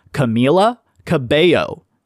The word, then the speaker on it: Camila Cabello: So, it's Camila Cabello, not Camila Cabello or Camila Cabello, Camila Cabello.